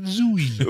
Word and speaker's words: Zooey: How old is Zooey Deschanel, and does she really pronounce it Zooey? – Oh yes.